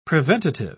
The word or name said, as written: preventative